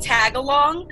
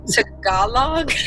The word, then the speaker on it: Tagalog: And we were talking about the language spoken in the Philippines, and I pronounced it wrong. I said, like, the Girl Scout cookie, Tagalong, and one of the students was like, Tagalog? – Oh, no.